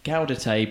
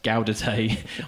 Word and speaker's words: Gaudete: Gary Lord has also written in, say, not meaning to be a pedant, but steely-eyes man, Gaudet... It's steely span Gaudete, pronounced Gaudete.